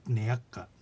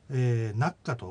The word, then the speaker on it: ねやっか: はい、これはあの例文の解説の中でも述べましたけども、あのねやっかほとんどあの普通の速さでも、特にあのソラチの出身者の人はナッカというふうに発音します。逆にあの上古壇から上流旭川の人なんかは、かなり速くなってもねやっかというふうに、はっきりと発音を昔はしました。